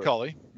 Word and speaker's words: Culley: David Culley.